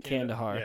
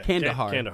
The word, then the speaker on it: Kandahar: After the Soviet withdrawal, Omar established and taught at a small village in the province of Kandahar. Am I pronouncing that right? – Kandahar. – Yeah. Kandahar. Thank you.